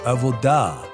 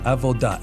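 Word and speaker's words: Avodah (עבודה): Okay, we have the pronunciation now. Avodah. That's what I'm told. Avodah. A-V-O-D-A-H. In Hebrew is Avodah.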